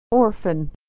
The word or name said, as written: orphan